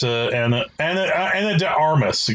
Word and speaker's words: Ana de Armas: but ultimately it is mostly Ana de Arias. I hope I'm pronouncing her name right. I'm sorry, Miss Ana de Armas.